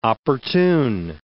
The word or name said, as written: opportune